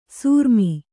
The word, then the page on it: ಸೂರ್ಮಿ: sūrmi